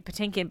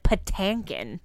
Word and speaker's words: Patinkin: I'm friends with Mandy Patinkin, but he pronounces it Patankin.